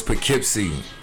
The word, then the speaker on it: Poughkeepsie: Oh, it's Poughkeepsie. – How far is that from, like, another major city? – We're about 45 minutes from the Bronx. – Okay, so I know about Poughkeepsie.